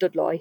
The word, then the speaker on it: Dudley: So Dudley, for example, is pronounced Dudloy.